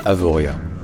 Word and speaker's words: Avoriaz: En fait, en savoyard, ou en franco-provençal plus généralement, le Z à la fin d'un mot n'est pas censé se prononcer Z. Il est censé indiquer qu'il y a l'accent tonique sur l'avant-dernière syllabe. Donc en l'occurrence, ce serait sur le VO. Donc ça ne se dit pas Avoriaz, ça se dit Avoria.